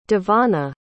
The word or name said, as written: Devanna